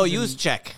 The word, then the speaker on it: Juszczyk: Kyle Juszczyk. – Juszczyk. There's all those J's. No, Juszczyk.